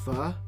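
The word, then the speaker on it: phở: And I'm supposed to talk about my pho and my fried rice, but it's pronounced pho, not pho, number one.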